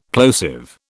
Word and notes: plosive: British English

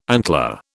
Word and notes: antler: British English

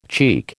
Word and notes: cheek: American English

